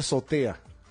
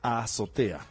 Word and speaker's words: azotea: Y no se dice sotea. Se dice azotea.